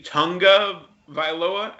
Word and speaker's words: Tagovailoa: and that's Tua Tagovailoa. I learned recently that it's supposed to be Tunga Vailoa. But there's definitely an N in there somehow.